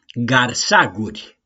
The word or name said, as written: GARSAGURI